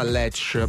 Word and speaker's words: Latch: perché la canzone era Lech, però si scrive Latch, okay.